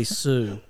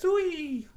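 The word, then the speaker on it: Siu: You couldn't have got it more wrong, to be honest. It's actually Sue.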